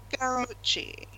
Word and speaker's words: Scaramucci: Scaramucci.